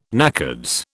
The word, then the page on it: NACODS: British English